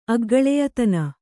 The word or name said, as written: ಅಗ್ಗಳೆಯತನ